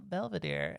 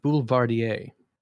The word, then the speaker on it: Boulevardier: and you have yourself a Belvedere. And you know, I actually think it's pronounced Boulevardier.